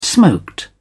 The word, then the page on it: smoked: British English